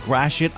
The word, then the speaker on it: Gratiot: Apologies to the people of Gratiot County in Michigan. That's actually how you say it, Gratiot.